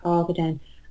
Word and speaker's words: Hargaden: It's Hargaden. Cathi, C-A-T-H-I, Cathi Hargaden.